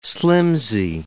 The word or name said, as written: slimpsy